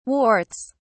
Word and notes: warts: British English

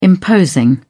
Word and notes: imposing: British English

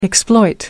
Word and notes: exploit: American English

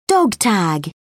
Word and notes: dog tag: British English

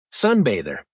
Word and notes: sunbather: American English

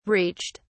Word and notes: reached: British English

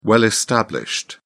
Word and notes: well established: British English

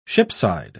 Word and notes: shipside: British English

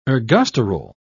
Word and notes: ergosterol: British English

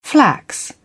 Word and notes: flax: British English

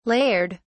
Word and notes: layered: British English

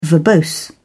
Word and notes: verbose: British English

